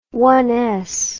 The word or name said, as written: ones